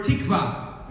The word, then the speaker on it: tikva: So the word for hope, the Hebrew word, is the word tikva. Am I saying that sort of close? – Tikva. – Tikva. Tikva. Accent on the second syllable. Second syllable.